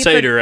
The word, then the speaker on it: Seder: Seder, as it's commonly pronounced mm-hmm. in... English.